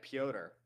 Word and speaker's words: Petr: David M says my pronunciation of Petr is rough. I thought that's his name, Petr Yan. – I think it's like Piotr.